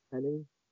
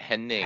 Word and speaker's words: Hennig: Um, so going in, I've really only ever experienced 90s Curt Hennig. Um, and and by the way, I gotta say it right. It's Hennig, not Henning.